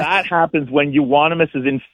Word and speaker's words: Euonymus: Is it unimus bush? – Euonymus. – Say it again. – Euonymus. – Euonymus.